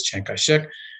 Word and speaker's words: Chiang Kai-shek: Chiang Kai-shek is his name. The southern pronunciation from the south is Chiang Kai-shek, was not a Democrat.